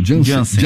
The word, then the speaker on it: Janssen: a vacina da Janssen, né? – Uhum. Como é que é? Uhum. Eu falei certo? – Janssen. Janssen, Janssen.